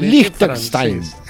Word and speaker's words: Liechtenstein: A ver, pronuncie con Liechtenstein. – Eso. Qué pena, me produce emoción oírlo brutalizar. Muy bien.